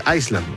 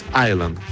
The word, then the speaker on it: Island: Es como Island, que en inglés se pronuncia Island sin la S, se queda mundo. Hay gente que dice Iceland, no, es Island, como Coney Island de Nueva York, o digamos, claro, lo mismo que design, no es design, así que Foreigner.